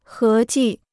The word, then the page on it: 合计: hé jì